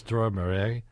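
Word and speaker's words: Dror Moreh: My guest is Dror Morey, who, am I pronouncing it right? – Yeah. Perfectly.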